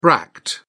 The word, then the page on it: bract: British English